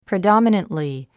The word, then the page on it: predominantly: pridɑ́mənəntli; プリドォミネントリィ